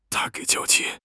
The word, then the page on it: 娇气: HSK6